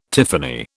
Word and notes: tiffany: British English

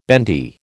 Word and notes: bendy: American English

